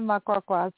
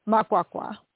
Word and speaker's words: Makwakwa: It's Vangile Makwakwa.